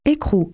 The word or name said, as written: nut